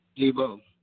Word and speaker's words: Debo: Debo. – Could you spell that, please? – D-E-B-O.